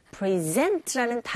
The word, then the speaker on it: present: 선물이란 뜻인데, 이 명사는 똑같은 철자로 present라는 다른 발음과 억양, 그리고 강세가 다른 위치에 있는 경우, 동사로 쓰여서 수여하다, 증정하다라는 의미로 쓰이게 됩니다.